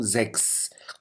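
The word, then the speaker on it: sechs: الزكس هون تلفظ لتسيها كحرف اكس، لاحظوا زكس. اما لما بتجي بال 16 فبتصير زشتسين، صارت مثل حرف الشين باللغه العربيه، زشتسين زشتسين، بينما هون زكس، لاحظوا مثل حرف الاكس تلفظ هون، هون اكس هون شين.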